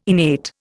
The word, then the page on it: innate: Indian